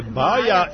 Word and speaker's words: Baja: Zašto su Nikola Pašića zvali Baja? I da li dobro akcentujem? – Jeste, Baja i...